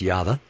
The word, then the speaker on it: Yahweh: If you look in the concordance, it's not L-O-R-D, it's Y-H-V-H, or pronounced Yahweh.